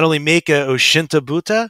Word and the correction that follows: Otoshibuta: am I saying that correctly? – Just Otoshi Buta. – Oshinta Buta. – Yeah, it's a Otoshibuta. Yeah, otosu means drop, so drop lid, otoshibuta.